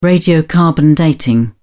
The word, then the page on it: radiocarbon dating: British English